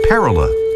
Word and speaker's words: parula: Well, enough doggerel about a bird whose name is pronounced, say the etymologists, parula, as in northern parula, today's featured feathered friend.